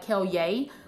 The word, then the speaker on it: Kellye: Yeah, I have my own podcast, Kellye Talks, etc. – Okay. – It's Kellye Talks. My name is spelled like Kellye, K-E-L-L-Y-E.